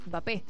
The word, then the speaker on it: Mbappé: Bueno, Mbappé, nosotros lo pronunciamos mal. Él siempre aclaró que es Mbappé. La Mbappé. M no se pronuncia.